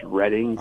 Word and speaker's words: Reading: Reading, Pennsylvania, that sometimes gets mispronounced as Reading, but it's Reading, Pennsylvania.